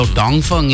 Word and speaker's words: Dongfeng: So, Dongfeng is how you say Dongfeng in China. – Yeah. – We just say it like very as local as can be.